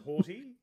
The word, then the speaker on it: haughty: What is, and am I saying that? I'm saying that right? Haughty. – You are. You're saying it right. – H a u g h t y. – Yep, haughty, as opposed to h a w t y.